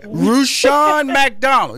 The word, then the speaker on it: Rushion McDonald: No. Rashawn McDonald. You know what I'm saying? If people call me Russian or rock, you know, I say, hey. Look here, say my name right, because that's part of your mm-hmm.